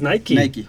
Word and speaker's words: Nike: Oye, pero una pregunta, ¿sí se pronuncia Nike o es Nike? Nike. Sí, es Nike.